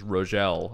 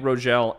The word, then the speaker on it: Rogel: Teleplay by Randy, I believe the pronunciation is Rogel. – Sorry, Rogel. – I was messaged and corrected. – Oh, man. – Teleplay by Randy Rogel and Martin Pasco.